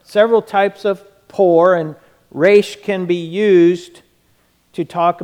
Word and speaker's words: resh: The Hebrew letter is resh. It's pronounced this way. Resh.